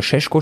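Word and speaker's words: Šeško: Salzburg ist sowieso so ein Verein, die haben Schesko, Bro. Oder Schesko spricht man den aus.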